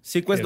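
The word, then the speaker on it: seaQuest: Perdón por mi pronunciación. – No, es que no. – Sequest se llama la serie. Sí, ajá. Sequest 2032.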